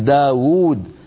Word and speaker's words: داود: باب ما حذفت منه الواو، مثلا مثل داود هي في النطق بواوين لكنها في الخط بواو واحدة.